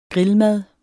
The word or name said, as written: grillmad